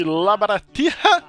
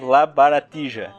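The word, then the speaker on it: La Baratija: É, estamos fazendo uma promoção com o site Labaratija. Olha, se, se liga na minha pronúncia do espanhol. Para, para os leigos, Labaratija.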